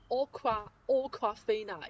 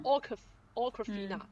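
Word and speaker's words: Awkwafina: 其实应该是念成 Aqua Aquafina，因为它的这个单词是取自于 awkward，就是很尴尬那个意思，是 Aqua Aquafina，就是很尴尬的菲娜吧，应该这么说。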